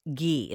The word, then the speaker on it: ghee: Now, 2 tablespoons ghee, is that how you pronounce it? – Yeah.